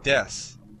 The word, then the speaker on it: desu: But next I want to go over desu. Desu, actually, it's written desu, but when pronounced quickly, it sounds like des.